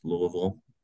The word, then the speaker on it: Louisville: So I live in Louisville, pronounced Louisville, Kentucky.